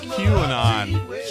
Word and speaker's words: QAnon: He said to talk about, is it Canon? Q-A-N-O-N? Did I say that right? – Oh, he's talking about QAnon.